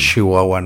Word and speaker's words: Chihuahua: Es un meme muy conocido en México de que pronunciamos mucho la sh. – Es que lo pronunciamos más como una sh, ¿no? Decimos Chihuahua, ¿no? – Sí.